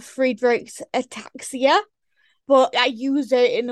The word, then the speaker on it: Friedreich's ataxia: It's called Friedreich's ataxia.